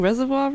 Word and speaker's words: Reservoir: It's certainly not got the French influence pronounced in it. Reservoir. – Oh, reservoir. – Because it's spelled like au revoir.